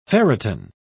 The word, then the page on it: ferritin: British English